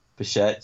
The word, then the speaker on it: Bichette: I don't have any scouting reports on them, but I think Bo Bichette. You told me how to pronounce that. – Bichette.